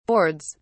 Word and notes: boards: British English

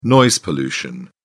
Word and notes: noise pollution: British English

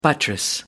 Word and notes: buttress: American English

